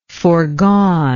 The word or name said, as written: foregoneness